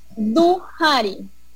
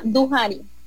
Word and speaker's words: Duhari: ¿cómo pronuncias el nombre de tu empresa? – Es Duhari. Es Duhari. – Duhari. Es francés y wolo africano.